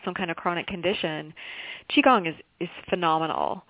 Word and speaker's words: Qigong: Yeah, it's actually Qigong. I like how you say it better,